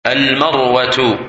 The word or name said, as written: Marwah